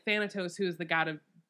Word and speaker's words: Thanatos: Let's see, Thanatos. I don't even know if I'm saying that right.